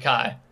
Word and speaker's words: Cay: C-A-Y? – I was going to say K, Kai, Clay. It looks like Kai, K, but yes. They pronounce it Kai.